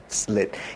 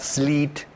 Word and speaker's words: slit: So I would take it out, slit. It's also, I find difficult for Japanese to pronounce it because it's not sleet, right? Surito, something, because there's an L there, it's an I.